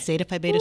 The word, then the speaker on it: Zeta Phi Beta: but you're under the umbrella of Zeta Phi Beta. Did I say that correctly? – It is Zeta Phi Beta Sorority Incorporated.